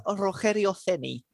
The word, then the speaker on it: Rogério Ceni: It might be Rogério Ceni is how I would say it. – Okay, that's the Spanish pronunciation then. Yeah. – Yes. – Okay then.